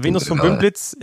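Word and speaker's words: Venus vom Bümpliz: Venus vom Bümblitz? Ich weiß ehrlich gesagt gar nicht. – Venus vom Bümblitz, ja genau.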